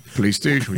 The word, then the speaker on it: PlayStation: Um Playstation. Se é que eu estou pronunciando certo, né? – Playstation, isso aí.